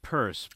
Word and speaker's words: Pierce: Yes, and now we hear that it there may be a different pronunciation of Pierce, but I've always heard. – Someone wrote in to say that in his home state it's pronounced Purse, but I've never heard that.